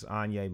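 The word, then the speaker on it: Anyi: It's Anya like Kanye. – No, it's actually not spelled like Kanye at all. – No, it's not spelled like Kanye. Like that's Kanye how it sounds. It's A-N-Y-I.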